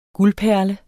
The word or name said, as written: guldperle